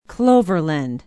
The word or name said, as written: Cloverland